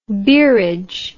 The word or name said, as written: beerage